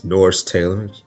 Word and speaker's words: Norris Taylor: My name's Norris Taylor. It's spelled N-O-R-R-I-S-T-A-Y-L-O-R.